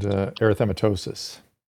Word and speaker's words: erythematosus: So the systematic, the discoid, and the, I can never say the other one, the erythematous, I can never say it right. – Discoid, systemic, and erythematosus. – Yes, thank you. It's a tongue twister.